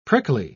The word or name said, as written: prickly